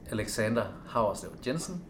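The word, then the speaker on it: Alexander Hauerslev Jensen: My name is Alexander Howarslev Jensen, and I'm the CCO, and it's spelled A-L-E-X-A-N-D-E-R, new word, H-A-U-E-R-S-L-E-V, new word, J-E-N-S-E-N.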